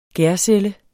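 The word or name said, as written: GÆRCELLE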